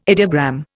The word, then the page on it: ideogram: Indian